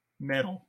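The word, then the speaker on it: mettle: September 16th through the 21st, test your Mattel? Am I saying that right? – Metal. Test your metal. – Okay, but that's M-E-T-T-L-E.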